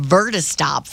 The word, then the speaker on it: Vertistop: The airport is building a temporary Vertistop. Is that how you say it? – Mm-hmm. – Vertistop for electric vehicle takeoff and landing aircraft.